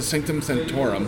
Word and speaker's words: Sanctum Sanctorum: Just because at one point, Spider-Man goes to the Sanctum Sanctorum. Did I say that right? – Yeah.